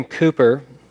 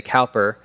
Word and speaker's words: Cowper: William Cooper, Brits say Cooper, the English say Cow, uh, Cowper, was a, um, or Americans say Cowper.